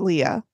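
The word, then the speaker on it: Albert Lea: She is from Albert Lee. That's pretty Leah. – Close. – It's pronounced Leah.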